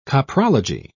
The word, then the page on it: coprology: British English